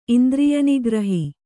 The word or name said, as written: ಇಂದ್ರಿಯನಿಗ್ರಹ